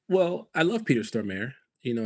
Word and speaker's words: Stormare: I thought I looked it up and you didn't pronounce the T. I thought the T was silent. – Well, maybe. I don't know. But whatever you said the first time was still incorrect. – I've always heard Peter Stormare. Like if we're saying it incorrectly, maybe. But that's how I've heard other people say it.